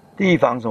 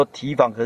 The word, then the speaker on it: 提防: 那么由于你还在细论里面呢，防普瞻呢，你不敢怎么样，防是提防，地防就是提防，地防什么？普瞻呢？普瞻是什么？ – 哪个地？ – 就是平常我们说提防，可是念成地防。